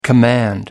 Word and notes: command: American English

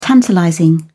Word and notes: tantalizing: British English

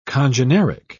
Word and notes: congeneric: British English

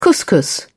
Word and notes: couscous: British English

